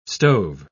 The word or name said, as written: stove